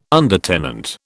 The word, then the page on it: undertenant: British English